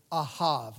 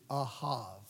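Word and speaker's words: ahav (אהב): But here, the word friend is actually the Hebrew word ahav. Let me spell it for you. It's A-H-A-V, ahav, ahav.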